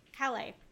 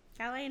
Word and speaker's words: Calais: Calais. Calais in France.